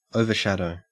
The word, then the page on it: overshadow: Australian English